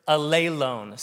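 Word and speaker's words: alelone (ἀλλήλων): See, that second accent is where you put the accent. Greek's cool because you know where to put the emphasis. You put the emphasis on the right syllable. And in this case, it's on the eta, so alelone. That's how you say that.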